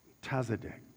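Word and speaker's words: tzadik (צדיק): It's spelled T-Z-A-D-I-K, and it's pronounced like the word exotic. It's tzadik.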